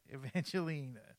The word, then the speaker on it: Evangeline: That's right, Evangeline.